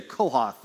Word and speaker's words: Kohath: Kohath on the count of three. One, two, three. Kohath. He was the chief guardian of God's tabernacle under Moses. And here's what you need to know about Kohath.